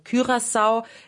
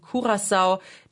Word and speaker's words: Curaçao: Beides kann man sagen. Curaçao ist die niederländische Aussprache und Curaçao, die Papiamentu-Aussprache, das ist die karibische Kreolsprache, die auf Curaçao gesprochen wird.